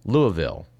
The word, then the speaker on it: Louisville: out of Louisville, which apparently is how they pronounce it in Kentucky.